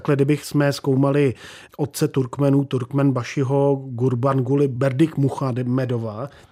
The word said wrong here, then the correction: Berdimuhamedov: teď jsem to řekl správně skoro, ještě jednou, Gurbanguly Berdikmuhamedov, Berdimuhamedov. – Muhamedov,